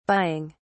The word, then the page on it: buying: British English